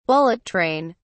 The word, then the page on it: bullet train: British English